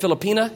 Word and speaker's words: Filipina: And how do you say it? Filipina, if you're a lady, and Filipino, if you're a guy.